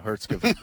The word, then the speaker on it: Herzegovina: Thank you, Herzegovina. We'll talk about that game coming up on Wednesday. Like you're adding a syllable in there.